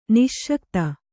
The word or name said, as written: ನಸಿಗುತ್ತ